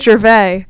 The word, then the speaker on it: Gervais: Pronunciation. Gervais! Oh my gosh, Gervais? It's Gervais. That's wild. Ignore me. – Yeah, that was a crazy... – Like, it's like the comedian.